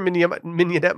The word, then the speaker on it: mignonette: Mignonette. – Mignonette. Okay. I would have guessed that, but I didn't want to go in confidently and sound like an idiot, martini. An oyster mignonette martini at Mars served with an East Coast oyster on the side.